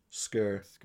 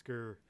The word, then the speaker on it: Sker: It's spelled S K E R, Skur. Skur.